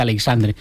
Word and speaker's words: Aleixandre: Espero que Vicente Aleixandre, cuando inauguren la placa, lo pongan Vicente Aleixandre, porque mucha gente lo pronuncia de otra forma, ni siquiera Merlo, que era su segundo apellido, ¿no? A Vicente Aleixandre se le conoce como Vicente Aleixandre.